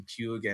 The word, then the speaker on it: Puig: And we're going to see if it pays, you know, dividend, especially, you know, especially everyone is on fire with the Ricky Puig and I can't even say his name right.